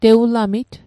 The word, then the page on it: tewulamit: Mi'gmaq